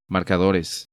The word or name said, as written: Marcadores